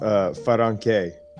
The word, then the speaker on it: Faranke: So mine is like the way it's pronounced in like the language. So it's a Ratuman last name, which is like Fiji Islands. – Yeah. – And it's pronounced Faranke. – Yeah, nice. – But like how we say it, like, you know, in an English form is like far, F-A-R, like a distance.